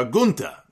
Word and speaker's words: Gunther: After the match, he hijacked the microphone, and he declared the winner of the match as Gunther. or Gunta, as he pronounced it.